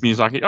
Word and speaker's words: Miyazaki: My Neighbor Totoro came out in 1988, directed and written by Hayao Miyazaki. If I said that correctly. – Miyazaki.